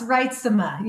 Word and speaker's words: Reitsema: Did I say that correctly? – Close, Reitsema, yes.